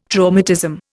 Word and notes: traumatism: Indian

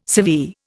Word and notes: severe: Indian

